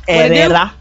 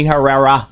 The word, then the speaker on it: Herrera: You have to roll your R's. – I'm white. Herrera. – Yeah, if you get pulled over by the police, it's Herrera. I'm Destiny Herrera.